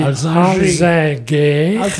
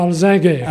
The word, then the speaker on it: Alzinger: La tendance à la française, c'est de prononcer Alzinger, ou à l'allemande, Altzinger.